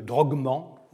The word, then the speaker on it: drogman: Vous savez peut-être que les Égyptiens prononcent le « dj »,« g », donc « targuman » donne « drogman ».